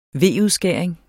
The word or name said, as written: V-udskæring